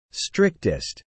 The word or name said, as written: strictest